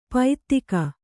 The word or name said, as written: ಪೈತ್ತಿಕ